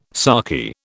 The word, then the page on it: sarky: British English